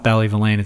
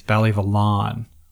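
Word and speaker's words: Ballyvolane: Sent me a DM and said, it's not Ballyvillane, it's Ballyvillon. Pretty sure that's how you pronounce it.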